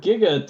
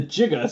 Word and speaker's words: giga: they famously mispronounced the word giga to jigga, so, because the scientist character, Doc Brown, called it gigawatts.